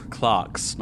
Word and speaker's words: clerk: It's one of those annoying ones that's spelled with an E, but said with an A. – Boo, English. – Clerks, not clerks.